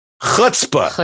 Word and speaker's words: chutzpah: It takes a lot of chutzpah. Is that how you say it? – Chutzpah.